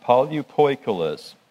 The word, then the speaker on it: polypoikilos: This word manifold, where it talks about the manifold wisdom of God, this word manifold is a Greek word, polypoikilos, is what it's the way it's pronounced, polypoikilos.